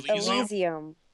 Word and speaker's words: Elysium: From a photographer aspect, I can see why that I see Asylum guy. Wait, is that how you pronounce it? Asylum? – Elysium. – Elysium.